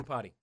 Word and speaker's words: Iupati: Iupati.